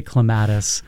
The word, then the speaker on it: clematis: Now, you say clematis, I say clematis. – Clematis. – Oh, that's the correct pronunciation. – They're both acceptable. – Okay. – Clematis is the preferred, but I don't care how you pronounce it as long as you have one in your garden.